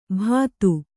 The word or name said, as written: ಬದು